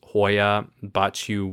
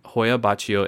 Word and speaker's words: Hoia Baciu: This one is the, I believe it's pronounced Hoia Baciu Woods in Romania. Hoia Baciu in Transylvania has captivated attention of the wrong sort for more than half a century.